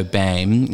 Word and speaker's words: Boehm: My surname is from, so Boehm is the pronunciation of my surname, at least through Galveston Island, you know, the Americanized version, but it would have been Boehm, B with an, you know, umlaut over the O, and that's from Bohemia, from this area is the people.